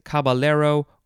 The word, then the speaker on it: caballero: And then lastly for this episode, we have, you could pronounce it a couple ways, caballero or caballero, C-A-B-A-L-L-E-R-O. I personally prefer the second pronunciation, caballero, because it is a Spanish word, and then when there's the two L's next to each other, they have a ya sound, like a Y, instead of an L.